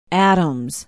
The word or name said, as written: adams